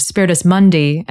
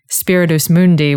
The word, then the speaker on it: Spiritus Mundi: As a side note, I just want to say that I love the fact that you have the proper classical pronunciation of Spiritus Mundi, and I'm saying Spiritus Mundi, which is bringing out my ecclesiastical Latin background. So we're kind of the personifications of the Greco-Roman and the Judeo-Christian traditions, okay. Just in our pronunciation of that term.